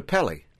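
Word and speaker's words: Kpelle: It's just easier for Americans to transliterate to see it, but the proper pronunciation. She's absolutely correct. The K is silent. – Right. – It's K-P-E-L-L-E.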